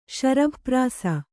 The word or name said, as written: ಶರಭಪ್ರಾಸ